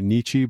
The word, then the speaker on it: Nietzsche: It's a secondhand reference to, I want to say Nietzsche, but I'm probably pronouncing that incorrectly.